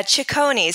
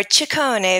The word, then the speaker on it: Cicone: It's a Cicone. So, or Cicone, if you want to add the Italian spin Cicone.